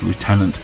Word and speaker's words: Lieutenant: And yes, I pronounce it as Lieutenant because I date an American.